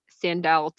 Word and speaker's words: Sandahl: Um, you can find me on any social media at Sandahl Taylor. Um, my name is very weird, so I'll spell it for you guys. It's S A N D A H L, and my last name is Taylor, T A Y L O R.